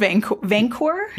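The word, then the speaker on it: Vanqor: It's called Vancor. Yeah, Vancor with a Q. – Yeah. It's Vancor with a Q. – Yeah, and it's luxurious. – The U is silent.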